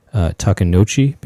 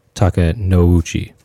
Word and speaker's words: Takenouchi: I said Takenouchi because I was thinking of it like Sozo. But I guess it's actually pronounced Takenouchi, and it's two syllables.